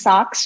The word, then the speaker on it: socks: 会有这样子，你在找手套的时候，可能要找这样子的部门，gloves，gloves，OK，嗯，socks，因为它也是两只，所以呢，通常都是一起买哈，所以要加 s，sock，注意一下这个 o 的声音是啊的声音，不要念成 socks，socks，因为它有一那个有一个就是在讲说什么东西很糟很烂，是一个很比较粗俗的一种说法哦，it sucks。